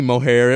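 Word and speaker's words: Moher: It might be mohair. It might be more. but Google tells us it's Maher with an Irish accent, which we do not have.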